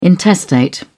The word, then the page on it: intestate: British English